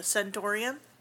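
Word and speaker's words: Centorium: I really liked the old name, the Centaurium, rhymes with Emporium.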